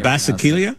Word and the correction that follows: Basilica: Yeah, that's not how you say it. – I just have a picture of it. The Basilica.